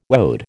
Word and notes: woad: American English

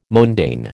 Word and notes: mondaine: American English

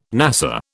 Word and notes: NASA: British English

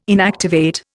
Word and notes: inactivate: Indian

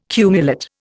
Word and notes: cumulate: Indian

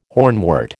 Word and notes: hornwort: American English

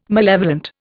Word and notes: malevolent: Indian

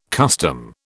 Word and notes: custom: British English

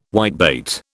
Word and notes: whitebait: British English

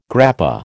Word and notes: grappa: American English

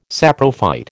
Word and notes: saprophyte: American English

